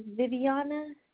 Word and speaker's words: Viviana: Yes, first name is Viviana, spelled with the letter V-I-V-I-A-N-A, and last name is Morales, spelled M-O-R-A-L-E-S.